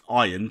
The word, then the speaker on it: Ian: Now, season one dealt with the power clash between McElhenney's Ian, pronounced Iron, because he's a dick, and Charlotte Nicole's lead programmer, Poppy Lee.